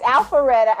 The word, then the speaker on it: Alpharetta: It's Alpharetta.